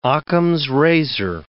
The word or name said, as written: Ockham's razor